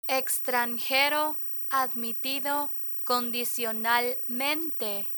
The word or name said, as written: extranjero admitido condicionalmente